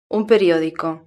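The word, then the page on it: un periodico: Spanish (Spain)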